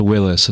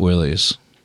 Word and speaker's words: Willys: This is a Willys, presumably. And technically, it's pronounced Willis and not Willys.